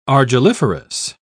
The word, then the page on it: argilliferous: British English